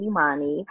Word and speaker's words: Imani: Yeah, but, you know, so, I mean, y'all can catch me on Instagram, Queen Imani. It's spelled exactly like that. Queen, I-M-A-N-I.